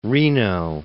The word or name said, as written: Reno